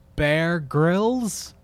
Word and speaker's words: Bear Grylls: Netflix's next interactive series puts you in charge of Bear Grylls. Is that how you say his name? – Yes.